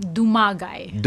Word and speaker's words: Dumagay: Imah Dumagay.